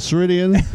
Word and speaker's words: Ceridian: It starts with a Ceridian and ends with a Ceridian. – Rhymes with Meridian.